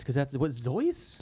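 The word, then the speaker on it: Zoës: Zoe's. – Okay. Zoe's. – Yeah. – That's rhymes with Rolls Royce.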